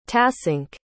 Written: tasunke

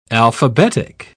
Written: alphabetic

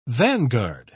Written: vanguard

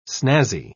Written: snazzy

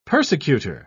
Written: persecutor

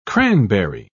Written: cranberry

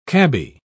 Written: cabby

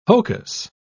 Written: hocus

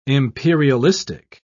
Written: imperialistic

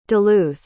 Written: Duluth

